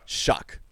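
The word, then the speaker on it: Shough: How do you say Tyler Shuck? – Shuck. So Shuck.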